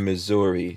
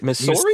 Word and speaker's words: Missouri: CJ says no Z in Missouri. So what I'm saying, what am I supposed to say? Missouri? Is it Missouri? – Missouri?